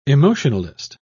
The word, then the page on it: emotionalist: British English